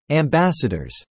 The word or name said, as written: ambassadors